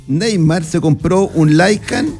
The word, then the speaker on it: Lykan: Escribe, amigo, para que ustedes sepan, lo busquen, se escribe L-Y-K-A-N. Lycan.